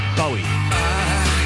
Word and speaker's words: Bowie: I always thought it was Bowie, but I thought, well, this is a Scottish name, must be Bowie. But nobody in Scotland pronounces it like that. They pronounce it Bowie.